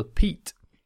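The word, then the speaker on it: Piet: And the latest model is called the Pete. Yep, it's called the Pete.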